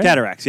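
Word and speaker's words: Cataractes: I said that right? – Cataracts. Yep. You got it.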